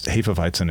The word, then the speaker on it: Hefeweizen: They both said, no, there's no schwa, which was part of the correction. And it's Hefeweizen is a totally fine pronunciation. Alan said, if you want to get into the really deep weeds, that second E, Hefe, is pronounced like the E in less, as opposed to Hefe.